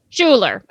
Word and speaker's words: Schuyler: So today's topic, tell me if I'm saying it right. James Schuyler? – James Schuyler. – Schuyler. Okay. Not the Schuyler of Hamilton, Schuyler sisters. – Oh my gosh. – Even though it's the same spelling.